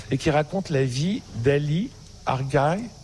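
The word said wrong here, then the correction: Ağca: Alors, ça se prononce Aksa en français, mais en turc, Aksa, puisqu'il était turc.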